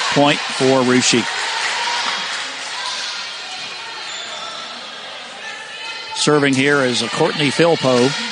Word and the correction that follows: Flippo: Or I'm sorry, Flippo. It's pronounced Flippo.